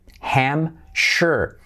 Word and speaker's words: Hampshire: New, lo mismo, New o New Hampshire, Hampshire, Ham, letra esa A como una E, y esa letra clave SH, Hampshire, Hampshire, I como E, y luego R, New Hampshire, New Hampshire.